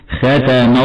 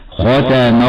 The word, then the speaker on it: ختم: من الأخطاء الشائعة عند نطق البعض لكلمة ختم ترقيق الخاء، هكذا خطأ: ختم الله على قلوبهم. والصواب أنها فخمة كما قلنا، هكذا: ختم الله على قلوبهم. ومن الخطأ أيضا تفخيم التاء تبعا لتفخيم الخاء قبلها، أو تفخيم الميم تبعا لتفخيم لام لفظ الجلالة بعدها، فيقرأ بعضهم خطأ هكذا: ختم الله على قلوبهم.